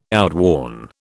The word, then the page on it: outworn: British English